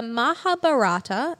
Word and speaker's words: Mahabharata: According to the Mahabharata, excuse me if I'm mispronouncing that.